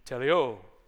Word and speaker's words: teleo: I'm trying to say it exactly like the pronunciation when I click on it, and he's like, teleo. So it's teleo.